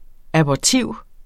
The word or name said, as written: abortiv